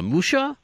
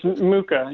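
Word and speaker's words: Mucha: Yeah, this is something that I wouldn't know, that I'm not hip to Czechoslovakian artists, but Musha, or is that how you pronounce it? Or? – It's Muka. – Muka. Yeah. I was going to say that, but I thought I was going to butcher it, so it is Muka.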